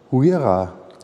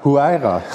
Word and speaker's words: Huayra: Ähm, Huira. Habe ich jetzt richtig Huaira. – Huaira.